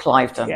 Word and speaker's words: Cliveden: There's a, a five star hotel near where I live called Cliveden. – Yes. Some pronounce it Cliveden.